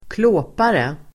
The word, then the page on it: klåpare: [²kl'å:pare]